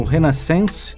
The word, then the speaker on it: Renaissance: Ele liderou na década de 80 a banda Asia, mas antes tocou no Renaissance, me informa aqui o Nilo Bairros, a forma correta de pronúncia, no Roxy Music, no Raya Heap e no Ashbone Ash, entre outras bandas.